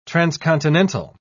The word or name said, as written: transcontinental